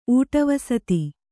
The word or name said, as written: ಊಟವಸತಿ